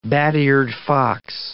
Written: bat-eared fox

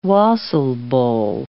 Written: wassail bowl

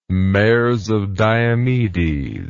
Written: mares of diomedes

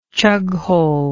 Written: chughole

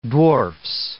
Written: dwarfs

